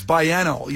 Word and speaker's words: biennial: It's biannual. You say E-N-N-I-A-L. You say it like biannual. – Spell it for me again? – Every other year. – Spell it again. – B-I-E-N-N-I-A-L.